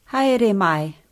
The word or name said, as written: haere mai